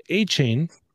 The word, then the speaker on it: Achane: The 202, Devon Achane. Say it with me, everyone. Devon Achane.